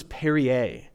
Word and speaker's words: Perrier: This was Perrier is the official way to pronounce it.